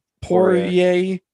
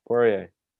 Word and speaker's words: Poirier: How do you pronounce that exactly? Poirier. That's what I thought. – Poirier.